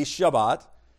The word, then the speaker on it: Shabbat: The word Sabbath comes from the Hebrew word Shabbat, sometimes pronounced with a B, Shabbat, which means to rest.